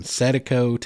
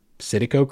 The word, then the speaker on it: Citico: And it had multiple spellings and multiple pronunciations throughout history and by different people, from Setico to Citico to Satapo. So just work with me. Citico Creek is how it's spelled. That's how I'm pronouncing it tonight.